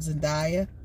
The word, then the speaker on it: Zendaya: And we're referring to Zendaya.